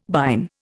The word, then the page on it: bine: Indian